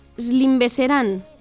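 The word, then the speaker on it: Schlimmbesserung: Vale, sí, la siguiente proviene del alemán y se pronuncia Slimbeserán.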